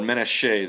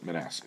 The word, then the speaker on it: Menasheh: Or Menasheh, as it's supposed to be pronounced. And I've been butchering that forever, and Ronan laughed in my face when I called it Manasseh. He's like, Manasseh, what's Manasseh? – Wait, what verse?